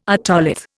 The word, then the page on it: otolith: Indian